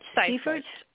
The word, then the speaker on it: Seiffert: Seiffert.